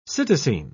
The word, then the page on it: psittacine: British English